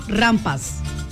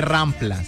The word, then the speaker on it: rampa: Aquí está escrito rampas. – Rampa, es que se dice rampa. – Por eso el señor dice que no. – No, que no se dice rampla, se dice rampa.